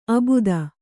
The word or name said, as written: ಆಬಾದು